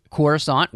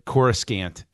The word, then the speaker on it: Coruscant: Who else pronounced it Coruscant in their head or Coruscant? I never made the C silent until I heard it pronounced elsewhere. And I was like, and even in the Jedi radio drama, they say Coruscant.